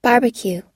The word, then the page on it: barbecue: long-u